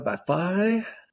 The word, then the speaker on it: Vafa: Today we are going to be talking to Sara Vafa.